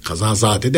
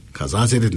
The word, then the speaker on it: kazazede: Kazazede. Kazazade denmez. Kazazede denir.